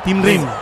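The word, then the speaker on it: Tim Ream: ¿Cómo se dice el 13 del zurdo que juega en el Charlotte de Estados Unidos? – Rim. Tim Rim. Tim Rim. – Tim Rim. Muy bien.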